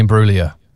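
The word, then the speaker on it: Imbruglia: So how do you say her name? – Natalie and Bruglia. – Yeah, nice. – Yeah, silent G.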